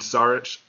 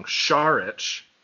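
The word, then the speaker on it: Šarić: Every one of those guys between Saric, or I've been saying it wrong, Saric, Baines, and Kaminsky, all of those guys can shoot threes.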